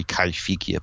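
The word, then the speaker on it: Kaiwhekea: Kaiwikia, or supposedly pronounced properly Kaifikia, but written Kaiwikia. And Arthur Cruikshank always said it Kaiwikia.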